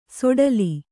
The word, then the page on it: ಸೋಡಲಿ: sōḍali